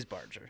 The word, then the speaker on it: Barger: It is Barger, yeah. – Okay. – He barged right into Barger, that poor girl's abdomen.